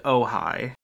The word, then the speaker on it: Ojai: And now we go on to Ojai, which is pronounced Ojai.